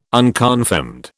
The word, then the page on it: unconfirmed: British English